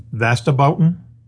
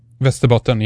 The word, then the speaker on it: Västerbotten: And they were first seen over the part of Sweden, which, you know, I understand is pronounced Västerbotten. – Västerbotten, yes.